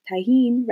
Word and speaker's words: tahine: Selling mangoes with a little bit of tahine, right? – Yes. Yes. That's exactly how you say it.